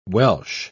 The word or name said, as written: Welch